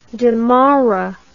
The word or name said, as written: damara